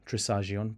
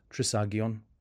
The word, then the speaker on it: Trisagion: Sometimes we may say Trisagion, but it's pronounced Trisagion.